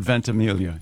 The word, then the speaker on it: Ventimiglia: Ventimiglia? – Does he go with the soft G or does he do the Mike Birbiglia thing? – I have heard the soft G. – I've heard Ventimiglia, yes.